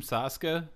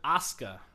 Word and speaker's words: Asuka: How do you say her name? Sasuke? – Asuka.